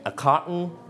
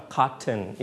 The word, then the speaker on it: carton: เราสามารถออกเสียงได้ 2 แบบ a carton or a carton it's okay don't worry